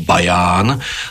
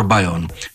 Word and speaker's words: Bayonne: Roger Sellers si totiž zvolil za svoj pseudonym francúzske mestečko pri hranici so Španielskom, ktorého názov miestny vyslovuje ako Bayonne, lenže po anglicky je to vraj skôr Bayonne.